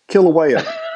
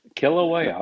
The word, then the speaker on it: Kilauea: Kilauea. – Is that what it? Boy, you must be a radio announcer. So that's in Hawaii. – Yeah. – Kilauea, okay.